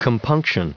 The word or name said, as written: compunction